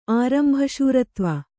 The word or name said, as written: ಆರಂಭಶೂರತ್ವ